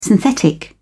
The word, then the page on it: synthetic: British English